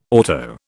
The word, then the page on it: auto: British English